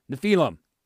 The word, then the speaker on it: Nephilim: And Nephilim is the transliteration. In other words, if you were to pronounce it out in English, that's what it would sound like, Nephilim.